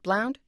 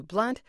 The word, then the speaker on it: Blount: Was it Blunt or Blount? Three pronounced it Blount, two Blunt, so both pronunciations are listed.